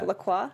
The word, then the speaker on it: LaCroix: And one of them on the list was, I think I'm pronouncing it correctly, LaCroix. – Yeah, LaCroix.